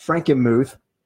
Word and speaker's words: Frankenmuth: he had been up at Frankenmuth.